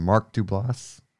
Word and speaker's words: Mark Duplass: Mark Duplass? – Yeah.